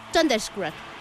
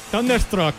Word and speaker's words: Thunderstruck: The Razor Age, álbum que incluye el clásico infaltable Thunderstruck. Algo así. – Thunderstruck.